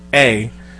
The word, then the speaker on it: Kentrella: Yeah, Ken, spelled like kinfolk. Kintrella.